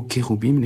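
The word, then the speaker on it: Kéroubim (כרובים): L'accent des mots hébraïques est sur la dernière syllabe, et là on le voit bien, c'est cette dernière syllabe du mot Kéroubim, les chérubins, qui est développée.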